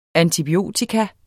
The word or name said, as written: antibiotikum